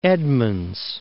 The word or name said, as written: Edmonds